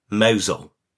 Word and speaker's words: Mousehole: And tis actually spelt Mousehole, but tis pronounced Mousehole.